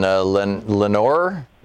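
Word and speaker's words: Lenoir: Marty in Lenore, North Carolina. Is that how it's pronounced? – Yes, very good.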